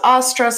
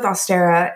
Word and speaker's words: Ostara: some people pronounce it something completely different. So, well, do you spell it differently too? – Nope. It's just Ostara. So I'm going with Austera. If you say it differently, that's totally cool. That is up to you.